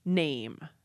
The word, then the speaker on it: name: We could take a word like name, N-A-M-E. That in Old English was nama, N-A-M-A, where you actually pronounce that last syllable. That last A over time became an spelled with an E, so you would have nama. And then the spelling got standardized, the final sound got lost, and we end up with a silent E at the end. Now, sometimes this worked out differently. The word meat, M-E-A-T, actually also had a final vowel at the end.